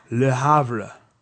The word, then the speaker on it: Le Havre: So it's Le Havre.